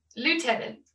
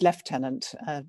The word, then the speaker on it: Lieutenant: So we've just actually had an interesting discussion before we started about pronunciation, because we'd been saying Lieutenant, but that's not how we pronounce it in the UK, or meant to pronounce it, is it? – No, it's really bizarre, because it's definitely spelt Lieutenant. And of course, we often hear that from the Americans. But in fact, it's really pronounced Lieutenant. Goodness only knows why, but that's how it's pronounced.